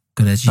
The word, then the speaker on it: Goraguer: So this Alan Goraguer, how do you say it? Goraguer. – That's him. – Yeah. – I thought it sounded a bit like the French version of Gallagher.